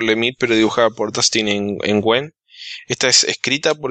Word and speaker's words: Nguyen: por Es vietnamita, se pronuncia Nguyen. Ignora la N del principio, es Nguyen. Dustin Nguyen.